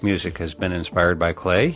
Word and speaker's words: Klee: And by the way, if you were a geeky engineering student like me, you may be pronouncing his name Klee, K-L-E-E, just as it's spelled. However, I have it on very good authority that Klee is a proper pronunciation.